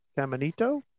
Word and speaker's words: Caminito: Augusto Caminito? – That sounds about right.